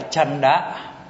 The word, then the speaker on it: chanda: Chữ này Bali đọc là chanda, chanda dục.